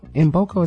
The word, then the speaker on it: Mboko: I want to say Mboko. Mboko is how you say it. Victoria Mboko is M. It's K-M-B-O-K-O.